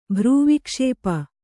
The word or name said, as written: ಭ್ರೂವಿಕ್ಷೇಪ